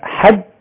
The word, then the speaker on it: حج: والحج تنطق بالفتح على الحاء وتنطق بالكسر أيضا، يقال حج وحج.